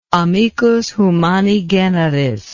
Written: amicus humani generis

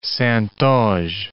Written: Saintonge

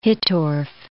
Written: Hittorf

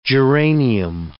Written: geranium

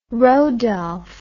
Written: rodolph

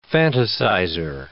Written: fantasizer

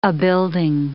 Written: abuilding

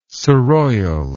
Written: surroyal

